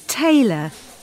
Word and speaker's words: Taylor: My name's Taylor. Francis Taylor. – How do you spell that? – T-A-Y-L-O-R. – T-A-Y-L-O-R.